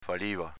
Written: faliva

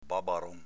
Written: babaron

